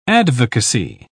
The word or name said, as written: advocacy